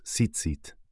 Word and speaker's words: tzitzit: La pronunciación de tzitzit es tzitzit, y las formas en plural son tzitzitot o simplemente tzitzits.